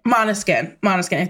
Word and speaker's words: Måneskin: So, you saw Måneskin. – Yes. – 2 nights ago. – Yeah, I saw... – Am I pronouncing that right? – Måneskin.